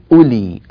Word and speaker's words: أولي: لكن في النطق كيف تنطق؟ تنطق أولي، الواو لا تنطق أبدا، هذه الكلمة تكتب فيها الواو ولا تنطق.